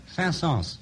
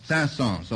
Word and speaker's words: Saint-Saëns: How do you say that? – Saint-Saëns. – Saint-Saëns, all right.